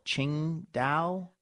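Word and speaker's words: Tsingtao: And when I'm at Din Tai, I would like to have, you know, usually like an Asahi or a Kirin or a Tsing Tao. Have you noticed how hard it is to pronounce the name of that beer? Some people call it Ching Dao.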